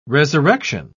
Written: resurrection

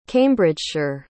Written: cambridgeshire

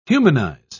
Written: humanize

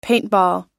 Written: paintball

